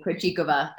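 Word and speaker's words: Krejčíková: Excuse me, Krejcikova. I have had to correct my pronunciation of her name. I was saying it wrong for years, which apologies to Barbara.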